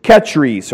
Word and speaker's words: Kechries: And today, Kenchrea is known as Kechries,